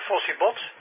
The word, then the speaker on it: Fossibot: Heb ik het goed uitgesproken, Fossibot? – Ja, klopt helemaal. Ja, Ja, Fossiebot, het is net als je een prehistorisch monster in huis haalt.